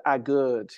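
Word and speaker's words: Aguerd: The West Ham boy, how do you pronounce it? Agurd? – Naif Agurd, yes.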